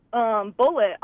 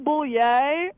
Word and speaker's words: Bulleit: And I saw someone handed me a note, and it said bullet on it. But I had no idea that that was how you pronounce a bourbon that's spelled B-U-L-L-E-I-T.